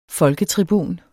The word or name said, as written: folketribun